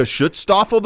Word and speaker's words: Schutzstaffel: Schutzstaffel.